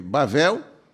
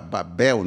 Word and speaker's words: Babel: foi Bavel Aí está Babel, né? Babel, vamos pronunciar aí, Erec, Acad e Calné, na terra de Sineá.